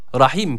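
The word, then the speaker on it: rahim (رحيم): Rahim kelimesi de rahim şeklinde okunuyor. Yani yine burada mide boşluğundan gelen H olarak okunuyor. Dikkat ediyoruz, burada H harfi yok. H harfi var, esrelidir.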